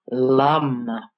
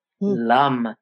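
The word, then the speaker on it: Lam: So the first chakra, which is all about earth and grounding, the seed syllable is lum, lum. And if you say that, it feels grounding. Say that for a minute.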